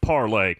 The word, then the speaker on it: Parler: It's spelled P-A-R-L-E-R, but it's pronounced parlay.